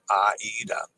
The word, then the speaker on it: AIDA: Is the I an I-E-Y-E or an E? AIDA in Spanish is just like it's spelled AIDA. Well, let's see what Dennis says. AIDA is of Italian origin and always properly pronounce AIDA.